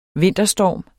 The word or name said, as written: vinterstorm